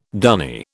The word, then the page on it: dunny: British English